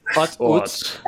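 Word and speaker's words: Utz: I've heard it pronounced both ways, so it's Oots, but they are better than Wise, and they're a low-end brand.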